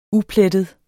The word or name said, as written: uplettet